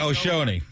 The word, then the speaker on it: Shohei: Shoney, is that how you pronounce his name? – Yeah, Shoney.